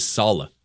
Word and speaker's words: Saleh: Props to Matt Maiocco for uh, tweeting out the pronunciation. It is Saleh, Robert Saleh, uh, and he was also with uh, Kyle Shanahan for four years with the Texans.